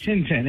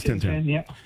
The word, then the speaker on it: Tintin: Is it Tintin or Titine? Or how's that pronounced? – Tintin. – It is Tintin.